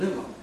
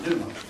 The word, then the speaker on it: pneuma: In Revelation, when it said that the false prophet gave breath to the image, this is it, pneuma. And you notice that silent P in English? It's got one in Greek to put a tie there. This is the word we're going to be concentrating on, pneuma, the word for spirit.